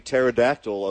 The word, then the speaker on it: pterodactyl: It's the pterodactyl of other words. – That's a P. – Yeah, I know. Silent letters.